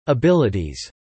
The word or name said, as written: abilities